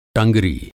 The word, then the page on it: टँगरी: Hindi